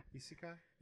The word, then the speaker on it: isekai: isekai.